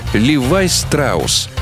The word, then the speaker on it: Levi Strauss: Непримечательное, казалось бы, событие, но Леб Штраус на английский манер произносится как «Левай Страус», а фирму, которую он позже основал, предприниматель назвал в свою честь – Levi Strauss Company.